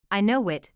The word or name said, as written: I know it